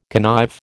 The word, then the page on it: connive: American English